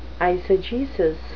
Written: eisegesis

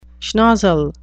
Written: schnozzle

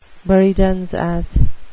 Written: buridans ass

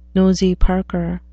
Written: nosy parker

